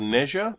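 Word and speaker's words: Nezha: It's called Neja, but it's spelled N-E-Z-H-A. N-E space Z-H-A.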